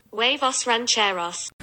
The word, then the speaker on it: Huevos Rancheros: Anyway, I rocked up with you and I saw this thing called Havros Rancheros. – I'll re-put in that pronunciation. – Huevos Rancheros.